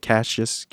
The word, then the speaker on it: Cassius: So the third uncomfortable moment with Fitzy was he did that kid Cassius Morris, is that how you say it? Cassius. – Just the first name, Cassius.